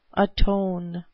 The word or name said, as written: atone